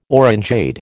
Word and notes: orangeade: American English

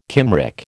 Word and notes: Cymric: American English

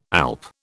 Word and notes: alp: British English

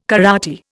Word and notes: karate: Indian

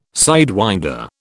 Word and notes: sidewinder: British English

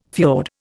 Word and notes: fiord: Indian

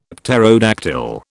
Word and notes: pterodactyl: British English

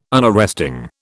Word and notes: Unarresting: British English

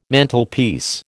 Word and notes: Mantelpiece: American English

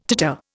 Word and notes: titter: Indian

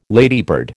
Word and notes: ladybird: American English

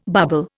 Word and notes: bobble: Indian

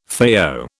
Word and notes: FAO: British English

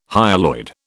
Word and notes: hyaloid: British English

